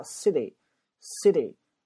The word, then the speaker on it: city: 后面是不是有一个气哔哔哔哔突然爆发？City, city, 还有个是很松散的 city, 对吧？所以这个 city 啊，这个单词的发音你要注意一下啊。